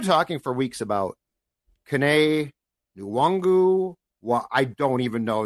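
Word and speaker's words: Nwangwu: Wongwu. The last two play plays is at Wongwu. – Okay, so let's go with his in college it was Nwangu.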